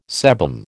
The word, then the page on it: sebum: American English